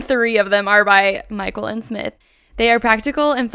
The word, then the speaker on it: Myquillyn: So one resource I look to in this arena of intentional homemaking are literally all three books by the author named Myquillen Smith. Her name is spelled M-Y-Q-U-I-L-L-Y-N. She says like Jacqueline and Michael put together.